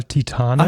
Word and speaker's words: Titane: In dem Fall Titane.